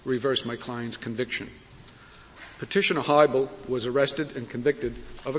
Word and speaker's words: Hiibel: Am I pronouncing your client's name correctly? – Your Honor, it's Hiibel. – Hiibel, very well.